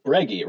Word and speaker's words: Breggy: So Breggy rhymes with Peggy.